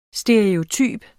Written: stereotyp

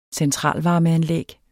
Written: vinterfoder

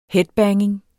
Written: headbanging

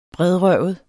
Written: bredrøvet

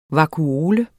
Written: vakuole

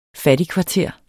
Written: fattigkvarter

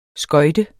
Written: skøjte

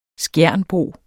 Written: skjernbo

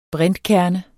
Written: brintkerne

